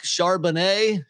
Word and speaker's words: Charbonnet: I'm going Zach Charbonnet. Sharp. Is that how you pronounce it? – Yeah, yeah. it is.